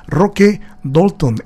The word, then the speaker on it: Roque Dalton: Roque Dalton, esta es la pronunciación, maestro.